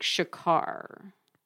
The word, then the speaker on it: Shakkar: Okay, then we're going to read Tastes Like Shakar. I guess that's how you pronounce it. S-H-A-K-K-A-R by Nisha Sharma.